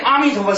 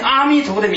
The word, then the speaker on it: 阿: 你看这个民国 75 年 11 月 10 号的这个出版的《菩提藏经》杂志里面登载祥云法师的所做的阿读阿的微妙所在的一文中说，念阿弥陀的名号有一点需要注意的，发音一定是阿，一定是阿，它是开发的上扬的，实现生命的生生不息。如果念成阿弥陀佛就有偏差了，阿的发音是嘴部缩出成一小圈，单从喉部发出的声音嘛，是轮回的音，轮回音是下沉的，所以不可念成阿弥陀佛，必须规规矩的念出阿弥陀佛的清朗音声。